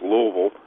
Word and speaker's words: Louisville: He's from Louisville, Kentucky, which, if you know the place, is actually pronounced Louisville, and